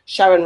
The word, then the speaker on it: Sharon: Sharon rhymes with Karen.